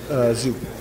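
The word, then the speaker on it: Tszyu: You know, you got Charlo and Tim Tizu. Is that how you say it? – Zhu. – Tim Zhu. – Okay, yeah. Sorry.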